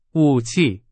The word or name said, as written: 武器